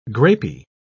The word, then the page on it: grapy: British English